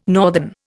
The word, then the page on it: northern: Indian